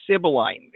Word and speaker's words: Sibylline: One thing I'm saying is, and your organization is called Sibylline.